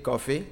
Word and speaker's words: Koffi: My name is Emil Coffee. Coffee is a real name because I was born on Friday. But it's spelled K-O-F-F-I.